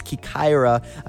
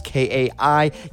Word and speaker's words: Kaikoura: I hope I'm pronouncing it correctly. I looked up how to pronounce it, so I believe I am. It's Kaikoura. Again, that's Kaikoura. This is a title track from her record.